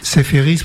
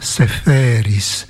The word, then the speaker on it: Seferis: En effet, Seferis, prononcé comme ça, a une sonorité claire, lumineuse, alors qu'en grec, la teinte est plus sombre, Seferis. Il y a du bleu dedans, mais c'est un bleu gris.